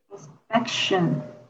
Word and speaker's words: inspection: Inspection. – 嗯，你也是那个，主要是你的“ 一” 的发音也是要稍微再放松一点。